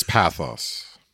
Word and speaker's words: pathos: It's in the ancient Greek, it's pathos. And the accent would be on the path.